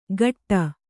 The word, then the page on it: ಖಟ್ಟ: Kannada